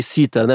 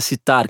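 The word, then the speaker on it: sitar: E citar aí, é como fala o nome correto, eu falei cítara, né, mas é citar, que é a pronúncia correta.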